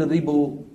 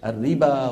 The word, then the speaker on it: الربا: نحو الألف المكتوبة في واوي الصلاة والزكاة والربا، ما أحد بيقول الربو ولا أحد بيقول الزكو، كله شو بيقول؟ الربا والزكاة.